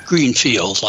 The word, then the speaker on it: Greenfield: My name is spelled A-L-L-E-N, Greenfield, like it sounds.